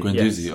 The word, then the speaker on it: Guendouzi: Guendouzi. – Guendouzi, okay, right.